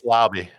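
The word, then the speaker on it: Laube: No, it's Lauby.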